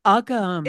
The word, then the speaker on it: Uggams: Uggams. – It is. Uggams. Oh my gosh. Okay. – Leslie Uggams.